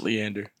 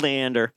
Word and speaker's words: Leander: Leander, Texas? – Leander. – Dang it. – Yeah. You're close. – Yeah, it's Leander.